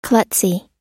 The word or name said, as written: klutzy